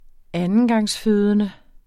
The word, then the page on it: andengangsfødende: [ ˈanəngɑŋsˌføːðənə ]; [ ˈanəngɑŋsˌføˀðənə ]